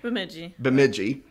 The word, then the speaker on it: Bemidji: Bemidji? – Bemidji.